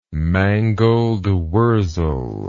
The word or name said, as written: mangoldwurzel